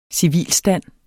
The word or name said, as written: civilstand